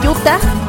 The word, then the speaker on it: Utah: Ahí para el Moy que dice, Utah, se pronuncia Utah.